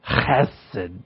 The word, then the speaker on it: Chesed: I pronounced this word wrong in the beginning, and now it's stuck in a neuron in my brain. And it had been pointed out to me how to pronounce it, so as you can see, that's why I'm struggling with the pronouncement. Chesed.